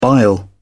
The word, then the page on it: bile: British English